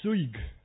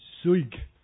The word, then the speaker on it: suyg: Second word, and this is my favorite in it, is the word for busy, and the word is suig. Try that. If I could spell that S-U-Y-G, suig.